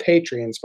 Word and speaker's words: Patreon: They're called Patreons, but it's Patreon. I like pronouncing the O.